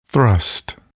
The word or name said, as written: thrust